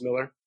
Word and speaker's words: Millar: But it's spelled in some crazy Scottish way with an M I L, uh, But it's pronounced Miller, uh, called Superman Red Sun, in which he lands in uh, Russia.